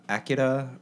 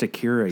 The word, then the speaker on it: Akira: That makes sense because wasn't he behind the Akira remake? – Mm. – The what? – It's pronounced Akira, you weeb.